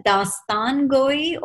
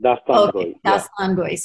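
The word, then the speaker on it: Dastangoi: So is it Dastan Goi, or is this? – Yeah, correct, absolutely. Correct. – Dastan Goi.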